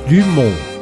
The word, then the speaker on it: Dumont: E a Santos Dumont era pronunciada Santos Dumont.